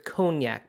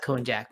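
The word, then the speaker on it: konjac: Yeah, no, it's probably pronounced cognac, but it's K-O-N-J-A-C, and it's a root, and it makes a really good vegan calamari with that.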